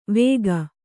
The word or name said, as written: ವಾಕು